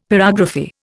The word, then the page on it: pyrography: Indian